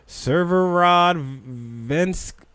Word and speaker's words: Severodvinsk: Severodvinsk.